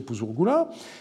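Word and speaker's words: Puzur-Gula: Les documents remontent au grand-père qui s'appelait Puzur Gula ou Puzur Ninkarak, c'est écrit de deux façons, mais on peut penser que son nom était prononcé Puzur Gula.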